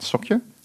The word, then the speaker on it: Socure: And you're VP Partnerships at Socure. – Correct. You did pronounce that correctly.